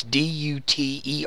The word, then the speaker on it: Duterte: And I'm sure I pronounced that wrong. It's Duterte.